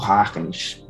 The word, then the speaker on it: Huygens: Porque, por exemplo, quando Newton publicou Ótica, ah, já existiam na época, por exemplo, o Tratado sobre a Luz do Christian Huygens ou Harkness, para ser mais correto em relação à pronúncia do sobrenome Calma dele.